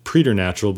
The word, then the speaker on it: preternatural: It is spelled P-R-E-T-E-R-N-A-T-U-R-A-L, preternatural. Some people like to pronounce it preternatural, but I think that kind of sounds pretentious.